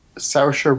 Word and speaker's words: Saoirse: Hey, how do you say Saoirse Ronan's name? – Saoirse Ronan. – Saoirse Ronan? – Yeah.